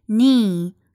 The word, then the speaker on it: knee: 这个词它的发音是 knee，是长音。